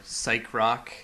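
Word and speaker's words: Psyché Rock: And that's because the Futurama theme is based on a song called CK Rock or Psych Rock.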